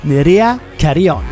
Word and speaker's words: Nerea Carryon: But in the meantime, I give you the one and only Nerea Carryon.